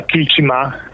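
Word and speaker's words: Kiltimagh: To się nazywa Kilchima. Kilchima. To jest małe miasteczko w Mayo, w County Mayo, małe miasteczko.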